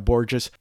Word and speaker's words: Borges: Moving right along, we're going to spend some quality time with the brand new record from Boston-based alt-country singer-songwriter-guitarist Sarah Borges, rhymes with gorgeous.